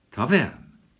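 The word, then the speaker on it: Tawern: Aus dem latinischen Taverne ist am Laufe der Zeit der Name Taverne entstanden. Entgegen dem, wie es am Deutschen üblich ist, bei ähnlichen Ortsnamen, leit heute Betonung auf der zweite Silbe, Taverne.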